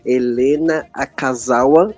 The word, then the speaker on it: Helena Akazawa: Eu falo hoje com a Helena Akazawa. Eu acho que eu falei certo. – Falou certinho, ó.